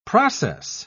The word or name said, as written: process